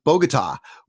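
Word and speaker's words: Bogotá: Well, there's probably lots of really interesting things, but the final one that I have here, last gig in Bogota. How do you say that? – Bogota was canceled because guerrillas bombed his hotel weeks before his arrival.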